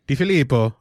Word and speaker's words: DeFilippo: There's a new offensive coordinator in John um, DeFilippo you're the Italian here. Did I say that right? Di, Di Filippo. Di Filippo.